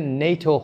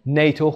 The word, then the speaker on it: NATO: مثل NATO که نیتو خونده میشه، North Atlantic Treaty Organization، سازمان پیمان آتلانتیک شمالی، نیتو خونده میشه.